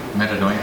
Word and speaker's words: metanoia: Metanoia. – Oh, metanoia. – Would you spell it? M-E-T-A-N-O-I-A. – M-E-T. – M-E-T-A.